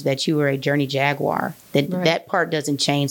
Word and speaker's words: Jaguar: I'm going to spell it phonetically for you. J-A-G-W-I-R-E. W-A-H-R. Jaguar. Say it with me.